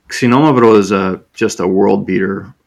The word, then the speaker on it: Xinomavro: Xinomavro. Xinomavro. Like a K-S.